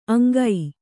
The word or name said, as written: ಅಂಗೈ